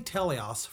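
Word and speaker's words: teleios: And just real quick, we say Telehouse.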